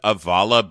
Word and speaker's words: Avalah: Okay, let's start with Avala Beauty. It's spelled A-V-A-L-A-H, Avala Beauty.